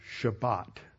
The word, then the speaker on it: Shabbat: When you are talking with somebody who is a Sephardic Jew or someone who is schooled in biblical Hebrew, they will talk about the seventh day of the week as the Shabbat, pronouncing the last letter as a T. But if you're talking with somebody influenced by Yiddish and Ashkenazi pronunciation of Hebrew, the final T is pronounced like an S.